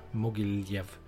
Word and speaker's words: Mogilev: Så han drar tillbaka i slutet av 2008 till FC Savit Mogilev. Mogilev. Uttalet är perfekt.